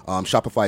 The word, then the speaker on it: Shopify: Shopify is spelled S-H-O-P-I-F-Y, like Spotify, but Shopify.